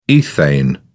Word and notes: ethane: British English

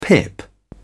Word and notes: pip: British English

